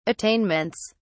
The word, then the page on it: attainments: British English